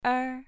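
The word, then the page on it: er: tone 2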